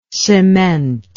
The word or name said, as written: cementless